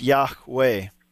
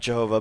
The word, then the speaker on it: Yahweh: So the point is that, it's pronounced Yahweh, not Jehovah.